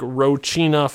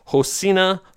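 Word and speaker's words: Rocinha: Yeah, because I mean, you look at it, it looks like Rochina Favela. But yeah, I looked in the pronunciation in Portuguese and it's Hosinia Favela.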